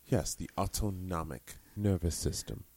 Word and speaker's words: autonomic: Experienced by males and females, orgasms are controlled by the involuntary or autonomic. What's the correct pronunciation? – Autonomic. – Autonomic.